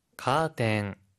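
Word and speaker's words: 카텐 (カーテン): 인테리아라고 할 때는 어가 아니라 아 발음이고 카텐이라고 할 때는 튼이 아니라 텐이라고 발음해 주세요.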